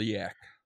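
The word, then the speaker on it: Leyak: And she wants to study a black magic, the leek, I think is how you say it. – Liak. – Yeah, the Liak, yeah.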